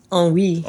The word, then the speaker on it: ennui: It saved me from, how do I pronounce, E-N-N-U-I, N-U-E? – Ennui. – Ennui, right.